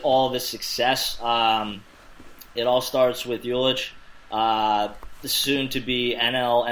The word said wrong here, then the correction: Yelich: Did I say that wrong, Tom? – Yelich. – all right, yeah, Christian Yelich. Nice.